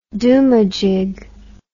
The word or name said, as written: domajig